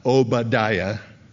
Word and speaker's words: Obadiah: Obadiah, that's how you say it, unless you're in Israel.